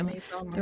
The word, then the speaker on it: Margiela: Is it Margiela? Is that how you say it? – Oh, yeah. – Maison Margiela. – Yeah, Margiela.